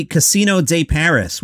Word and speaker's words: Casino de Paris: Inside the Casino de Paris, which is how you pronounce it in French, the 2,000-seat venue where he performed to two sold-out audiences, press not invited.